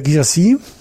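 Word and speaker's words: Guirassy: Girassi, haben wir richtig ausgesprochen? – Das klang sehr gut.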